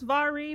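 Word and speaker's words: Mhairi: It's pronounced Vari, but it is spelled M-H-A-I-R-I. I'm pretty sure it's Irish.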